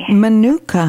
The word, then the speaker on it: Manuka: And now, what is that form, Thapka, Mananuka, am I pronouncing it correctly? – Manuka honey.